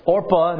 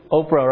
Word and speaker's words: Orpah: That's Orpah, not... I know you're thinking... Right? You're thinking Oprah, right? Right? You get a car, you get a... No. This is I almost said it wrong too. It's Orpah.